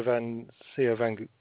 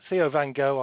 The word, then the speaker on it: Van Gogh: No, I think you said Theo van Gogh. Well, I normally pronounce it Van Gogh, but Theo van Gogh, I'm sure, is a perfectly okay way to pronounce it.